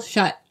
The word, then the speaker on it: Schutt: I pronounced the author's last name wrong, I believe, so I'm going to do it right now. It is Cannibalism, A Perfectly Natural History by Bill Shutt. I think I said shoot last time, and...